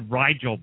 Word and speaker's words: Rigel: It's I think it's the Rigel Band is how she says it. yeah, it's like Nigel with an R.